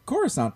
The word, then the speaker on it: Coruscant: And I pronounced Coruscant properly.